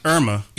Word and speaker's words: Irma: Hulk Hogan said some choice words from after Hurricane Ima. Hurricane Irma. – Yeah, Irma, I'm sorry.